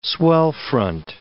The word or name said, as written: swell-front